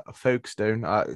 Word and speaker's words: Folkestone: We know what me and Pete are doing with Folkestone. Say how I pronounce it again because I'll get out of my way for the season. – Folkestone. – Whatever. Folkestone, that's it. You got it.